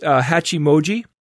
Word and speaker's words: Hachimoji: And they're calling the new eight-letter DNA language, I think it's pronounced Hachimoji, which is Japanese for eight.